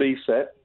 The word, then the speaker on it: ESET: The chief cybersecurity expert at ESET. Is it ESET? Is that how that's pronounced? I wasn't sure because I've only seen it in print. Is that the name of your company? – Typically called ESET, but in ESET, certain countries, okay, it's definitely called ESET as well.